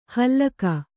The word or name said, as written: ಹಲಾಕು